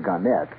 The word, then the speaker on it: Gannett: Gannett, or Gannett, they prefer to pronounce it Gannett, Westchester newspapers.